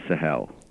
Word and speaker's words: Sahel: Uh, do we call it the Sahel? Am I pronouncing that correctly? Yeah, the Sahel. S A H E L.